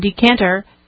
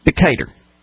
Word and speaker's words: Decatur: You got to get the correct southern pronunciation. It's Decatur, not Decatur.